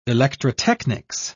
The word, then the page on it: electrotechnics: British English